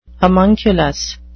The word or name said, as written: homunculus